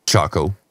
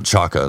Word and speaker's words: Choco: Choco. – Choco, okay. Choco. – You know Choco. – Yeah. – Not Chaka, that's something different.